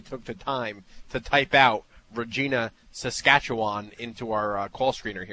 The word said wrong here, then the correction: Regina: It's actually Regina, just so you know. – Oh, that rhymes with...